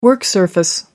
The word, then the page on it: work surface: American English